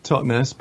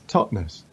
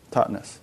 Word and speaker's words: Totnes: Everyone says Totnes, but apparently it's supposed to be Totnes. – Okay, so, Totnes.